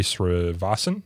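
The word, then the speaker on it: Srinivasan: Court of Appeals for the District of Columbia Circuit Judge Sri Srivasan, is that how you pronounce the last name? – Yeah.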